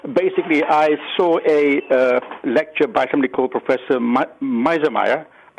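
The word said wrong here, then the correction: Mearsheimer: I'm sure I'm saying it wrong. University of Chicago. – Meersheimer. – That's the Meersheimer.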